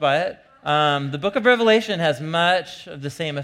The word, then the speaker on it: Revelation: This is from the book of the Revelation, not Revelations, with an S on the end. It's the most common mispronunciation of the book, the Revelation.